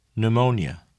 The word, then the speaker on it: pneumonia: The letter P is silent in the following words.Repeat them during the pauses. – Receipt. Psychology. Pneumonia.